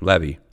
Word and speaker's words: Levy: Number one, his name is Levy and spelled like you you know would know Levi. Mm-mm, Levy.